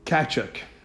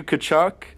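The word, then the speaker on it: Tkachuk: Yeah, so it's Johnny Gaudreau and Matthew Kachuk. – You clearly just looked it up, because that's not how you pronounce it. It's Matthew Kachuk and Johnny Gaudreau, also known as Johnny Ham and Cheese.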